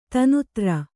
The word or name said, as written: ದೀನ್‍ದಾರ್